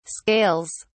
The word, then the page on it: scales: British English